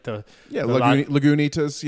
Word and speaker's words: Lagunitas: It's the Lagunitas. It's the IPA. It is fantastic. I think I'm pronouncing that beer right. – Lagunitas. Yeah, Lagunitas. Yeah, I think that's right.